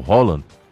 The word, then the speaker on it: Haaland: O Haaland, como ele mesmo disse, que é a pronúncia do nome.